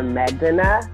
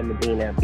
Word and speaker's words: Medena: Well, what's crazy is it's actually pronounced Maya Medena, because it means sweet, but in America, I just say Maya Medena because it's easy and it's spelled that way.